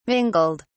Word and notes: mingled: British English

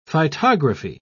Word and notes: phytography: British English

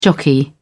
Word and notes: jockey: British English

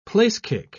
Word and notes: place-kick: British English

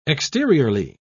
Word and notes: exteriorly: British English

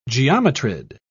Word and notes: geometrid: British English

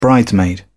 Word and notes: bridesmaid: British English

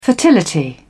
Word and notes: fertility: British English

